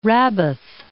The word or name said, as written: Rabbath Ammon